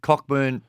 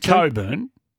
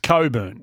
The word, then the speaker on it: Cockburn: Well, it's Coburn. – Is it? – It's Coburn is how you pronounce it. – He spelled it differently. I know. Well, that's how it's spelled. – Oh. – It's a silent CK, though.